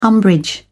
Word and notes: umbrage: British English

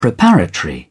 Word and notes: preparatory: British English

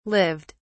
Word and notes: lived: British English

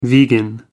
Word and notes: vegan: British English

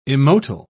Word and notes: immotile: British English

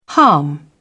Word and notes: harm: British English

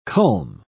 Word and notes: culm: British English

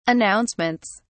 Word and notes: announcements: British English

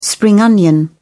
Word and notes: spring onion: British English

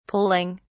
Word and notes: poling: British English

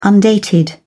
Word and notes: undated: British English

